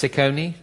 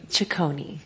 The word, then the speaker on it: Ciccone: I think is it pronounced Ciccone? Ciccone. – Ciccone.